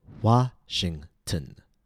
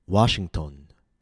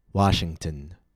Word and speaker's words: Washington: Notice how the letter O was reduced to schwa, tn. A common mistake is Washington. Washington. The letter O should be pronounced with a very short not O or A. Tn. Washington.